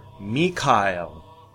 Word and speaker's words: Mikhail: Thank you, Michael, or Mikhail.